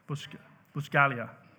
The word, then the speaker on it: Buscaglia: Is that right, friend? Buscaglia, silent G, of course.